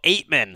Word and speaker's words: Ateman: You got Marcell Ateman. Is that how you say his name? As their number one wideout now. It is.